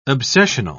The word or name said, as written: obsessional